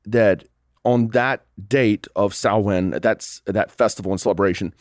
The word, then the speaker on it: Samhain: But the earliest origins are actually from before the time of Jesus in a Celtic festival known as Samhain. That's how it's pronounced in the Gaelic.